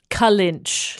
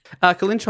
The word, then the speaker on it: Kellynch: Kalinch. – Kalinch. It's spelled Kelly, K-E-L-L-Y-N-C-H.